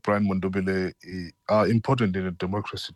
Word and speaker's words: Mundubile: Am I pronouncing his name properly? Brian Mundubile, yes.